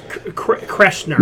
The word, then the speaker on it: Kreischer: For some more funny news, Mark Hamill just announced via Instagram that he will be starring opposite comedian Burt Kreischer. Is that how you say his name? – I think it's Burt Bacharach. – No, it's Kreischer.